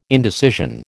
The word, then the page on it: indecision: American English